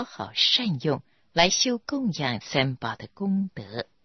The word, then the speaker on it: 供养: 附带说明一下供养三宝的样这个字的发音，自古我们中国人对上是讲供养第四声，对下是讲教养养育，养是读作第三声，这里是供养三宝读作第四声样。